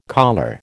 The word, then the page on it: collar: American English